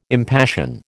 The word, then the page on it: impassion: American English